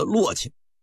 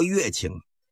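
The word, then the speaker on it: 乐清: 咱书要简言，单说戚继光率领着军队兼程前进，抵达这个温州北面的洛庆，这个地方也叫乐庆，就是音乐的乐，这是个多音字。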